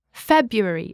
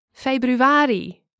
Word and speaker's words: februari: In English, nouns such as months normally have the stress on the first syllable. – January, February, etc. – In Dutch, the stress is usually on the second to last or penultimate syllable. – Januari, februari, etc.